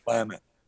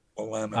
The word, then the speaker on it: Willamette: It's pronounced Willamette. Willamette.